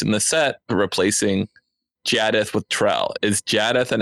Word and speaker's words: Jaddeth: And then he, in an unbranded-like characteristic, goes, it's pronounced Jaddeth, by the way.